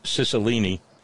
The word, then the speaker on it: Cicilline: Charlene in Rogues Island says, just a heads up, David Cicilline is my rep, and his name is pronounced Cicilline.